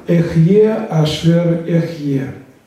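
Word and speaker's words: Эхье Ашер Эхье (אהיה אשר אהיה): Это имя, которое произносится в этом тексте. Эхье Ашер Эхье.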